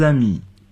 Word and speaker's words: amis: Jean et moi, nous sommes de vieux amis. – 我和 Jean 是老朋友了。复数形式的朋友就是在 ami 后加上 s，但并不发音。